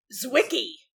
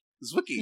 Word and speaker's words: Zwicky: And sorry if I mispronounce this. Zwicky Transient Facility. That's Z-W-I-Z-K-Y. – Zwicky. – Zwicky.